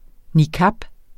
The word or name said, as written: niqab